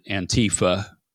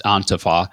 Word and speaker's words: Antifa: Some pronounce it Antifa. It's in the German, it's Antifa.